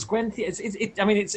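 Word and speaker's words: Gwenllian: Even the fact that Gwen Thian is pronounced as Gwen Thian.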